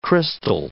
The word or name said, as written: crystal